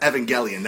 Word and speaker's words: Evangelion: By the way, I think there's going to be a lot of people killing because it's pronounced Evangelion. That's Evangelion? Yeah.